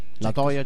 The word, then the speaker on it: La Toya: La Toya Jackson. – Sì, si chiamava La Toya. Alcuni sbagliavano la pronuncia. – Aggiungevano una R, possibilmente.